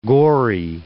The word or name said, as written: Gorey